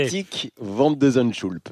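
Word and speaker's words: Botic van de Zandschulp: Boutique Van de Zandschulp. – Vous l'avez vraiment très bien dit.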